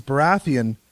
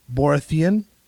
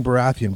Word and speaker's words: Baratheon: Week, and I was listening to the Game of Thrones Clash of Kings, and the reader pronounces Baratheon, Borathian, and immediately it clicked in my head exactly what Greg said.